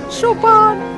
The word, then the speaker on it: Chopin: Es Chopin, Pili. Se pronuncia Chopin.